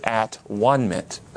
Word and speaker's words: atonement: This is at-one-ment, atonement. In the Old English, they used to say at-one. Have you ever heard of a word, A-L-O-N-E? How do you say that word? – Alone. – Not all one? It's alone, atone. Do you hear it? At-hone, at-one.